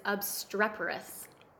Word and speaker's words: obstreperous: Okay, so our word today is obstreperous. – Obstreperous. I think I pronounced that right. Okay. – You did.